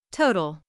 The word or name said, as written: total